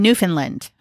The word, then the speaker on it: Newfoundland: Newfoundland. – Newfoundland. – Sort of like some of the letters are sort of disappear.